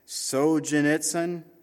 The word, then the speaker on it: Solzhenitsyn: Alexander, I even wrote down how you say it, but Solzhenitsyn, there you go.